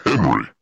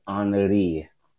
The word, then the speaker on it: Henri: I said Henry. – Yeah, because it's spelled Henry, but they told me over the weekend it's Henri.